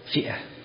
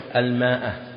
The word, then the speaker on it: مئة: وبعضهم يكتبها على وزن فئة، وهذا أقرب، وهو الآن هذا الاصطلاح رجع إليه الآن الكتب الأخيرة طباعتها يكتبون المئة على وزن فئة، أما بعضهم يكتبونها يعني الكتاب يكتب مئة ماء لكن ما ينطق، ولذلك بعضهم الآن إذا أتى يقرأ وما يعرف يقول الماء، لكن الأقرب أنها على وزن فئة، لأنه ليس في العربية شيء يكتب ولا ينطق، يسمونه في الإنجليزي اللي يكتب ولا ينطق سايلنت.